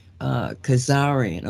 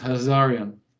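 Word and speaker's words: Khazarian: And I don't have it's Hazarian. It's with K-H. – Kazarian. Okay, that's why. – But it's not. It's pronounced Hazarian.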